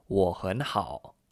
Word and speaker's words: 我很好: Since there are three third tones, we pronounce the word 我 and the word hun as second tone and kept hao as third tone. This gave us 我很好, I am very good.